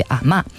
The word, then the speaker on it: 阿嬷: 在这儿要做一个小小的解释啊，我们在这儿说了阿嬷，这是普通话这个字的读音，但是电影里呢，大家会听到潮汕话说的是类似于阿嬷，所以后面大家听到的很多采访当中，大家也是用了这个读音。